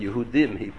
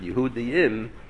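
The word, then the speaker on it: Yehudim (יהודים): The Gemara, the Yerushalmi says that if the Balkari made a slight mistake in Kriyas HaMegillah, instead of pronouncing the word Yehudim, he mispronounced it Yehudiim, but it's not Mishana so Inyan, so then you Yotze B'Yeved, and you don't have to lean over again.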